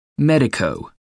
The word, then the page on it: medico: American English